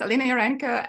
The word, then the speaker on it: Alina Yurenko: My name is Alina Yurenko, and I'm a developer advocate for GraalVM at Oracle Labs.